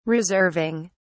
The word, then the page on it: reserving: British English